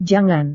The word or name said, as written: jangan